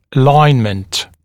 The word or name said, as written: alignment